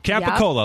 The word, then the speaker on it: Capicola: Capicola, yep. like Coca Cola, but Capicola.